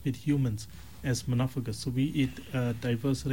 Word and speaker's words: monophagous: The word that we were looking for today, Marana, is the word monophagus.